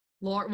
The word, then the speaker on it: Laura: No, you you really when I when you introduce yourself or you say your name, you really like enunciate the Laura. – Laura. Well, that might be the southern in me though.